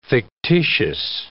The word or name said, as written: fictitious